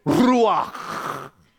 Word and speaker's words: ruach: The word in the Hebrew is ruach. Ruach. You got to have the CH. You got to go to... That's how you say it.